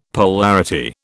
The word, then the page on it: polarity: British English